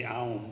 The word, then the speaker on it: Om: It's O-M. – O-M? – O-M. – Well, yeah, I mean, it is O-M. I mean, that's the actual syllable. Some Upanishad may say Aum, but it's actually Om.